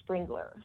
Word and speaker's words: Sprengeler: but I may have mispronounced your name. How do we pronounce your name? – My name is Chelsey Sprengeler. – I told folks it was Sprengler, so Sprengler.